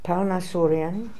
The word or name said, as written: palnassoorian